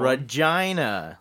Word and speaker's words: Regina: And the movie was filmed in Regina and Moose Jaw, Saskatchewan. – Regina. – That's how it's pronounced.